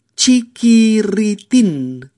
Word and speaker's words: chiquirritín: Esta vez vamos a empezar la primera, la de las cuatro ies Vamos a empezar por la última sílaba. ¿Vale? Tin. Repetid conmigo. Tin. Luego, ritin ritín. Después, kiritin, kiritin. Y por último, chiquirritín, chiquirritín.